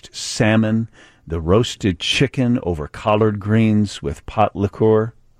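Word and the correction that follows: pot liquor: Liquor. if we're going to pronounce it.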